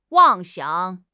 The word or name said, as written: 妄想